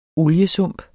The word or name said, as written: oliesump